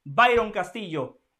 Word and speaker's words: Byron Castillo: No me equivoqué, ¿eh? lo dije bien, el colombiano Byron Castillo.